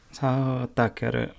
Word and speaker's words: Sato Takeru: And then we have Sato Takeru. Is that, do you know how to pronounce his name? – Sato Takeru. Yeah. Sato Takeru.